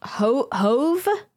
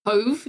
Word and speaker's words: Hove: This is going to be pronounced Hove. – Hove, yeah.